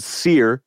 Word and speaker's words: Cyr: Their last album was called Seer, spelled C Y R.